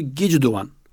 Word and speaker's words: Gicduvan: Bugünkü telaffuzu Gicduvan. Özbekler tarafından öyle telaffuz ediliyor.